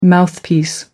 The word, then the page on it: mouthpiece: American English